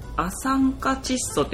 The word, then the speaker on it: 亜酸化窒素: これは亜酸化窒素って読むんですか、うーんって読めるね、